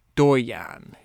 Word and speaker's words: doyen: Doyen is what Google's telling me how to pronounce it.